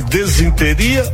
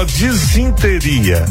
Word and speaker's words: disenteria: Você está com desenteria ou disenteria? Como é que você pronuncia? Ah, eu tô com uma desenteria danada ou com uma disenteria? Se você falou que é disenteria, tá correto, aí tá beleza.